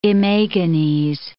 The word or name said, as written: imagines